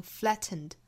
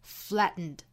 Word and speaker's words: flattened: 那当然我们完全可以把里面的 t 音发出来，发成 flattened，但是还有另外一种更多见的发音方式，就是 flattened。